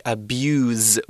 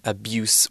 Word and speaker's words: abuse: Um, abuse can also be used as a verb as well if we pronounce it slightly differently. So it's spelt the same as the noun, but we say abuse with a Z sound rather than abuse with an S sound.